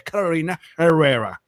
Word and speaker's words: Carolina Herrera: Disculpa, yo no, o sea, disculpa que te corrija, pero es Carolina Herrera. – La verdad, disculpa. – Que se lo pronunció mal.